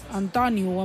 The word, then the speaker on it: Antoni: Diu, ell vol que li pronunciïn com si fos a la barcelonina. Antoniu, amb entonació, a sa O, no a sa U.